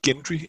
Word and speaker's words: Gendry: I tv-serien siger de Gendry. Jeg, jeg havde altid kaldt ham Gendry øh, op til der, men, men nu siger jeg Gendry, for det var åbenbart det, han hed der.